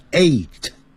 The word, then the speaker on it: eight: gh 不发音念成 eight